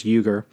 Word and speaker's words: Uygur: Their founder and creator, I believe his name is Cenk, um, I think he pronounced his name uh, Uygur or Uygur. I think it's Uygur.